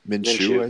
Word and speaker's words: Minshew: I think what you say, Minshew, is that how you say it, right? – Minshew, I believe it is.